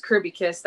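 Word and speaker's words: Kirkby: And phonetically, you had it right. It is Kirby Kiss. That K is silent. Some people get it on the first time. Some people don't. Some people do pronounce that K. But it's Kirby Kiss.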